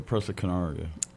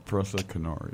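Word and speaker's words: Presa Canario: Persian Pashiansha? The Persiana? The really big dog of yours, the Persiana something? – Oh, the Presa Canaria. – My bad, I might have pronounced it wrong. – Presa Canaria.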